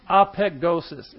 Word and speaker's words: apekdosis: Apegdosis is how you pronounce it.